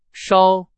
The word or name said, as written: 烧